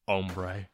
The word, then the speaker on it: hombre: Also, the H is silent, hombre.